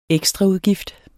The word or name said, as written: ekstraudgift